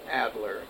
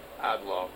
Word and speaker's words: Adler: But he didn't pronounce Adler in the approved fashion. So it came across as Adlow.